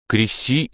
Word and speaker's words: Креси: Креси имеет ударение неизменно на втором слоге. Не вижу оснований ради размера искажать слова.